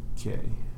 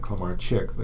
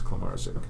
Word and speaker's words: Klemarczyk: Now, in Eastern Poland, the CZ is pronounced Z as in Tsar. But if I was from Southern Poland, it would have been Klemarczyk, like the CZ in Czechoslovakia. – Mm-hmm. – But anyways, it's pronounced either way. But my grandfather came from Eastern Poland, so it was Klemarczyk.